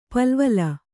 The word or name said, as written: ಪಲವಲ